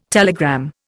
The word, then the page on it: telegram: Indian